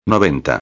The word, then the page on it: 90: espanhol